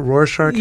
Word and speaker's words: Rorschach: Is that Rorschach? – Yeah.